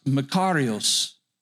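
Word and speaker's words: makarios (μακάριος): In the Greek, it's pronounced makarios, blessed.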